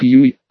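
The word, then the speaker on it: юй (鱼): Помню я сказку про золотую рыбку, но так старику одна приплывала, а эти, дуэтом, спросила, конечно, узнала, что рыба излишества произносится как юй,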